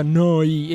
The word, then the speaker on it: Neu: Si scrive Neu, si pronuncia Noi e vuol dire nuova emittente urbana, 7 giorni su 7, 24 ore su 24.